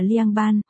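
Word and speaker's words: liang ban (凉拌): rau trộn trong tiếng Trung đọc là liang ban, mà từ lạnh cũng đọc là liang, có lẽ ở đây CSN ám chỉ TTD giải quyết bằng khí lạnh chăng?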